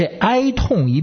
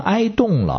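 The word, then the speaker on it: 哀恸: 痛字呢是左边一竖心，右边一个运动的动，所以像我这样文化低的文盲啊，就老把这字念成是动。这哀痛一变成哀动了，听起来就常常造成误会。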